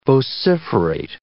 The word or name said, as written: vociferate